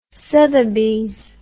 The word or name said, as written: sothebys